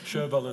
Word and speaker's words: Sjöwall: Sjöwall and Wahlöö.